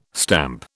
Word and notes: stamp: British English

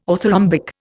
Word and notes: orthorhombic: Indian

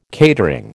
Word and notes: catering: American English

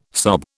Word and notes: sob: British English

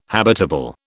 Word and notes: habitable: British English

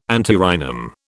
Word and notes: antirrhinum: British English

